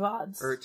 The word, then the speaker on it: Odds: Let's see, the most recent episode, season 1, episode 26, eight from the Age of Ads title track. – Age of Odds. – Age of Odds, sorry. – It rhymes with pods.